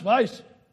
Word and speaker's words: Weiss: It's German, W-E-I-S-S, but you pronounce it Weiss, I'm told.